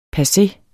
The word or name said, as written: passe